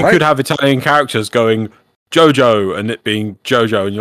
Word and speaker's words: Giogio: they Jojo in Italian is spelt G-I-O-G-I-O, which makes a Jojo sound, right?